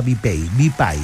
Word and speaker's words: BPAY: B larga y latina PAY.cl. BPAY se cree, BPAY, se pronuncia BPAY, ¿vale? – Exacto.